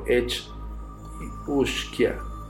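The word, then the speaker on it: each-uisge: Estos caballos de aguas mutantes, pertenecientes al folclore de Escocia e Irlanda, son conocidos como Kelpie o Edge Isue, pronunciado Edge Ushkia, y comparten ciertos rasgos.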